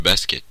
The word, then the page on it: basketball: [ˈbæs.kɪt.ˌbɔl]; /ˈbɑːs.kɪt.bɔːl/; /ˈbæs.kɪt.bɔːl/; /ˈbɑːs.kɪt.ˌbɔːl/